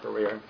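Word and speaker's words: Barer: Mark Barrier, M-E-R-K, last name spelled B as in boy, A-R-E-R.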